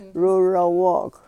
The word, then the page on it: rurowok: yurok